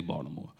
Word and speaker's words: Baltimore: Arthur is this man from Baltimore. I said it right at 9 o'clock, sir. I'm from Jersey. We say Baltimore.